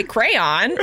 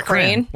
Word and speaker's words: crayon: So Mike Ryder is a crown guy. Brad Barnes, our good friend, says crown. I actually say crayon. – Crayon? – Yeah, crane. – Like a crane? – Like a crane?